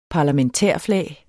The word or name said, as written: parlamentærflag